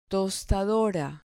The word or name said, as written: tostadora